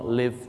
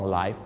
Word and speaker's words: live: Long live the king ครับผมขอให้ทรงพระเจริญไม่ใช่ Long life the king